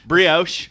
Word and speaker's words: brioche: Brioche.